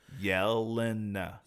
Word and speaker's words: Yelena: I can't say her name. Yolanda? – Yelena Belova? – Yolanda? – Yelena? – Yelena? – Yelena.